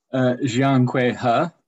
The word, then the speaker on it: Jiankui He: So The Mutant Project opens in Hong Kong in November 2018, when Dr. Jiankui He, have I pronounced his name correctly? – Jiankui He. – Okay, thank you.